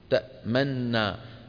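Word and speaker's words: تأمنّا: تأمنا، يعني بعد الميم كم سن في سن واحدة، فهذا بيأكد موضوع الإدغام، وأن النون الأولى مدغمة في الثانية، والنطق يكون بنون مشددة.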